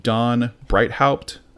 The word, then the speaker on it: Breithaupt: It's by a writer named Don Breithaupt, I think is how you pronounce that name.